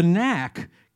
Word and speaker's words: Knack: I thought the Knack was a funny name. Get the Knack. – Uh, yeah. Silent K. Yeah. yeah. I love silent letters.